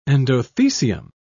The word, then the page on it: endothecium: British English